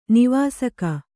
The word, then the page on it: ನಿವಾಸಕ: Kannada